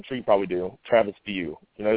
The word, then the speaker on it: Wiuff: Well, his name is spelled W-I-U-F-F, okay? You wouldn't get View from that any way you look nope. – at it. You're never going to look at that and say View, ever. You know what I mean? So I find out that's what the pronunciation is.